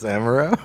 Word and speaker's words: amaro: Amaro. Am I saying that right? – Amaro? – Amaro? – Amaretto? – What is it? – Are you trying to say, is it amaretto? It's amaro.